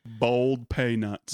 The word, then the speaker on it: boiled peanuts: And you pronounce it bold peanuts.